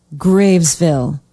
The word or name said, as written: gravesville